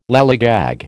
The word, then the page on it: lallygag: American English